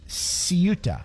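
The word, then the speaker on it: Ceuta: Ciuta is how it's pronounced.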